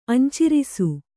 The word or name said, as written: ಅಂಚಿರಿಸು